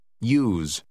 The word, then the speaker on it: use: Das Wort Nummer 9 wird ausgesprochen, als ob es mit einem Y im Anlaut geschrieben wird. Hören Sie und wiederholen Sie. – Use.